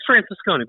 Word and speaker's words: Francescone: It's Francescone.